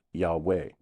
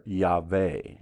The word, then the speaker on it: Yahweh: Well, some say it's a two-syllable name. It's pronounced Yahweh mm-hmm. or Yahweh. – Mm-hmm. – And then some say, no, it's a three-syllable pronunciation. It's Yehovah.